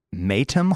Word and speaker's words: Maytham: Did you know that the robin was based on a real robin she met while living at, I'm going to butcher this word, Maytham Hall? Okay. M-A-Y-T-H-A-M. – That sounds right.